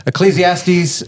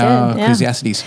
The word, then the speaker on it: Ecclesiastes: Ecclesiastes 4:9-10. Did I say it right? – You did. – I think you did. – Ecclesiastes.